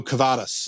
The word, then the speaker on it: Kavadas: and Niko Kavadas. Is that is that right, Jim? As our Red Sox guy. – Yes, you pronounced that very well.